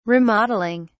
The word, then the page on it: remodeling: British English